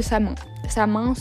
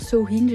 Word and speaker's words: Samhain: Pour les gens qui disent sowin c'est juste la prononciation qui change, rien d'autre.